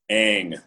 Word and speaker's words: Ng: Her first name is Kim, and then it's spelled NG, and the guy with the YouTube said it's pronounced N. So I don't know. How do you say it? – Ang, like A-N-G, oh. Kim Ang.